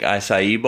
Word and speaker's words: acai: It is organic acai bowls.